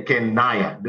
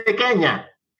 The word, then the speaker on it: Kenya: You don't say Kenaya, do you? No. Hello and welcome to Kenaya. You don't say that. You say Kenya. It's the same syllable, N-Y-A, Nyamoya, Nyasa.